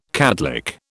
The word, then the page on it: catlick: British English